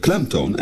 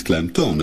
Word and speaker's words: klemtoon: De klemtoon van de klemtoon is natuurlijk klemtoon en niet klemtoon. Het is immers de toon die de klem krijgt.